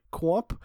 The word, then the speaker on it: Qomp: Well, Seth, the video game I've recently been playing is a game called, I believe it's pronounced like Quomp. It's spelled Q-O-M-P.